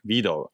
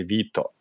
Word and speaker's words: Vito: Even though I go, my nickname even back when I was growing up back in Europe, like is Vito, or so like Italians or some of my friends would pronounce it as like Vito, more like a V-I-D-O or like, you know, pronunciation. But, you know, my friends in Europe would say Vito. It's like in Italian, Vittorio, Vito.